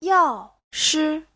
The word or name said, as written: yao4shi5